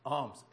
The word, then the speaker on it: alms: Alms, A-L-M-S. If my daughter's here, she would tell me that I know that you mispronounce it. It's alms. But I would tell her that English is not my first language.